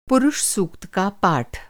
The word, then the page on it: सूक्त: Hindi